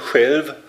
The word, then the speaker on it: själv: S-C-H-E-L-F. So wird es weder im Englischen äh, noch im Schwedischen geschrieben. Im Schwedischen gibt es auch ein Wort, das man so ähnlich aussprechen würde, Själv, das heißt selbst, was natürlich für uns äh, naheliegend ist.